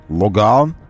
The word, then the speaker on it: Логан: Кстати говоря, как-то немного Логан, или как некоторые произносят, Логан, Логан, ну, французский манер, немножечко как-то ушел в тень за всеми этими нашими Солярисами и Рио, да, немножко как-то мы подзабыли.